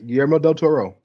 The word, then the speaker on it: Guillermo del Toro: Guillermo del Toro, right? Am I pronouncing him right? – Guillermo, yes. Guillermo del Toro.